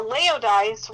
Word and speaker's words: Laodice: Now, Laodice rhymes with paradise, but I got to tell you that Laodice has nothing to do with paradise.